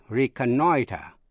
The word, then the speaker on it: reconnoiter: Reconnaissance, a reconnaissance mission, là một đặc vụ thám thính, viết tắt là recon, R-E-C-O-N. Động từ của nó là reconnoiter, reconnoiter, R-E-C-O-N-N-O-I-T-E-R, là thăm dò, trinh sát, lấy tin tức quân sự, từ tiếng Pháp reconnaître.